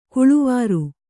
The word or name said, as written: ಕೊಳವೇರು